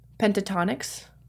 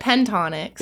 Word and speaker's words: Pentatonix: Not actually how you say it. I oh, it is. That is how you say it. – Oh, it is. He, yeah, see, he's messed correct, my brain up. he calls it pentonics.